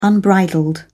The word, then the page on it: unbridled: British English